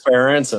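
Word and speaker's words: Ferentz: And, you know, Ferenz or whatever. And I couldn't pronounce the name and learned it was Ferenc.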